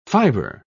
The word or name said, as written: fiber